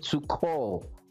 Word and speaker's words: Tuchel: Then for the Chelsea game, we are officially welcoming on the podcast Thomas Tuchel, as it's pronounced in German. I don't know if I'm sounding right, but it's pronounced Tuchel.